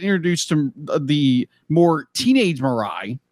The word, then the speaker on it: Mirai: Mirai. – Mirai's future self and their whole journey in order to, because me personally be like, I'm watching this first one.